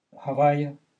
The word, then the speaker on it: Havaya: Questo è il nome Havaya, Yud Hei Vav Hei. C'è una forza enorme dentro queste quattro lettere. È tutto. Pronuncia questo nome come Havaya.